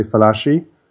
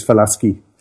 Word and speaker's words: Falaschi: By the way, most people say Falaschi. Mm-hmm. The correct pronunciation is Falaschi.